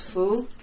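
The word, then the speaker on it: Fu (福): In Chinese, it's pronounced Fu, and I know that Weibo knows this character.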